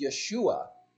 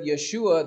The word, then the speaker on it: Yeshua: Instead of Yeshua, they pronounce his name Yeshu, which is an anachronism, which means, may his name be cursed.